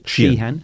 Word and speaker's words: Sheehan: Sheehan.